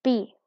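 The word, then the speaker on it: Bea: Number 29, Bia, or B, right? I'm saying it wrong. Sorry. B.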